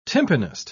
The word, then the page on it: tympanist: British English